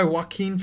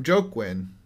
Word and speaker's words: Joaquin: I called him Joaquin, and then I found out it was Joaquin. In my defense, it's spelled that way.